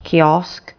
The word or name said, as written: kiosk